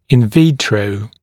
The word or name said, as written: in vitro